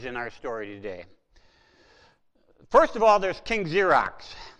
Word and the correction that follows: Xerxes: It's probably King Xerxes, but you know, it doesn't make any sense. I can't pronounce it. So King Xerxes, and he's also known in most places as Ahasuerus.